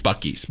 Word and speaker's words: Buc-ee's: I thought it was Bucci's. It's Buc-ee's, by the way. It's the long U, not the short U.